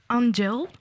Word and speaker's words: Angel: Et bonsoir à Héloïse, ici pour nous parler avec Joy de sa mixtape Angel. Je prononce bien à, oui, à l'anglaise.